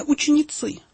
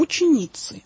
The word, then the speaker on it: ученицы: Мы как-то уже говорили, эта речь идет именно о лицах мужского пола, так скажем, потому что если бы это были женщины, скажем, мироносицы, то ударение было бы по-другому, ученицы. А вот здесь речь идет как раз об учениках, об апостолах будущих, и вот они поэтому ученицы названы.